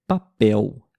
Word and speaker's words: papel: Temos a palavra, por exemplo, papel. Papel. Papel se escreve no final da palavra com a letra L. Mas quando a gente fala, o som é da letra U.